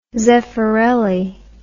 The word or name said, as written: zeffirelli